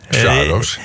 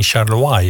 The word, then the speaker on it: Charlois: Sjaarloos, ja. – Ik weet dat dat zo uitgesproken wordt. Maar wat is nou de reden dat Sjaarloos geen Charlois is bijvoorbeeld?